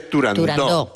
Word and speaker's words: Turandot: Se dice Turandot, que es... – Como lo pronunciaba Puccini. – Como lo pronunciaba Puccini y como lo pronunciaba Toscanini.